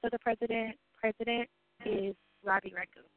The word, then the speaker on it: Raghu: R-A-V as in Victor I. – R-A-V-I. – And then Raghu, R-A-G-H-U. – R-A-G-H-U.